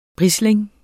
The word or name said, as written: brisling